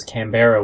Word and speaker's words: Canberra: Yes, they pronounce Melbourne well, they pronounce Canberra well, which of course is spelt in a way that everybody pronounces as Canberra when they haven't heard it before.